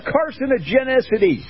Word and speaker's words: carcinogenicity: carcinogenicity.